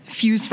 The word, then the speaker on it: Fyooz: So, so I think to start talking about what you have developed and are launching here in the community with um, Fyooz Financial. That's how you pronounce it, right? Yes. Okay.